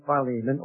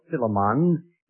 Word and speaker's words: Philemon: 1 and 2 Timothy, Titus and Philemon, or Philemon, either pronunciation is acceptable.